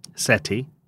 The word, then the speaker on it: Seti: I'll just read a couple of them, but there's one that I really wanted to show you at the temple of, I think it's pronounced Seti? – Seti. – Yeah, the temple of Seti the First at Abydos.